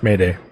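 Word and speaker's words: m'aider: derived from the French word "maider," I believe, that means help me. – No, actually, it's pronounced Mayday. – Gotcha. Okay. – Surprisingly enough.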